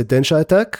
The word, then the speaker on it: Densha Attack: זה מויית Densha Attack במילה אחת, עם סימן קריאה בסוף, אבל זה מבטאים את זה Densha Attack.